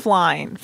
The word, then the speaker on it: Plein: Klein. – Klein? – Klein. – How do you spell that? – P-L-E-I-N.